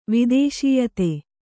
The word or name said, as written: ವಿದೇಶೀಯತೆ